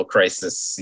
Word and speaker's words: crises: I feel like plural would be crises, but that sounds insanely wrong.